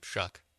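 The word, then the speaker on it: Shough: I mean, it's Tyler Shue probably. – Shuck. – Exactly. – Doesn't matter. – Exactly. – It rhymes with suck.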